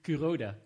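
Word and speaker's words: Kuroda: De plaat Takuya Curado. – Curoda. – Curoda. – Rode JC, hè? Rode JC. Rode JC. Ja, daar had je het ook kunnen herinneren. En het moet wel iets meer met uitspraak, man. Je doet het te Nederlands.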